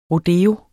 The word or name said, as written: rodeo